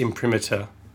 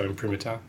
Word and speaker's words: imprimatur: Um, one of them is definitely just imprimatur, with a, a schwa at the end, which is just a short vowel sound. So imprimatur. Um, one at the end has an extra sound before the schwa. Maybe that me- that means imprimatur is also um, acceptable.